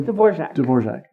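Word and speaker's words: Dvořák: Dvorak.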